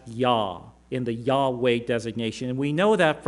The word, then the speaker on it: Yahweh: So we know that the name here, the YHWH pattern, was pronounced, most likely would have been pronounced had they pronounced it, as Yahweh.